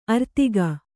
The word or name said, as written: ಆರ್ಥಿಕ